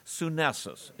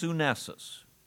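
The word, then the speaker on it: Sunesis (σύνεσις): Sunesis, it's pronounced. Sunesis.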